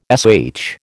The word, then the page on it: sh: American English